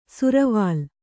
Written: ಸುರ್ವಾಲ್

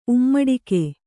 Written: ಉಮ್ಮಡಿಕೆ

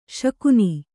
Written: ಶಾಕುನ

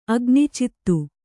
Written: ಅಗ್ನಿಚಿತ್ತು

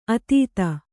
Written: ಅತೀತ

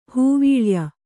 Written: ಹೂವೀಳಯ

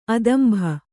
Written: ಅದಂಭ

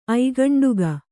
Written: ಐಗಂಡುಗ

